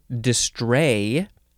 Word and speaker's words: distrait: The next word is, it is pronounced distray. Distray, but it is spelled D-I-S-T-R-A-I-T, distrait, but it is pronounced distray.